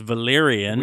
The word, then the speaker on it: Valerian: Am I saying that correctly? It is Valerian. – Oui. – What did I say that right? – Oui.